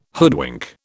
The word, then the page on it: hoodwink: British English